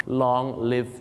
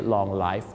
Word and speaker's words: long live: long live the king ครับผมขอให้ทรงพระเจริญไม่ใช่ long life